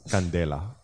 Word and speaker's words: Candela: So what is Candela? – All right.